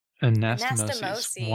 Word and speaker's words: Anastomosis: The anastomoses. – Anastomosis. Wonderful. Anastomosis. close. I was going anastomosis was what I was going to say, but that's yeah. the singular.